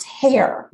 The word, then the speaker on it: Herr: All right, I'm going to talk to you a little bit about Sam Hare, and his last name is H-E-R-R, but it is pronounced Hare.